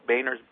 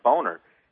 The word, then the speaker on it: Boehner: Well, I don't call him Senator Boehner. I call him Senator Boehner. – Yeah, well, you know, and arguably that's how his name would be pronounced if it was anglicized, but, you know, the obvious reference there is just a little too much for me.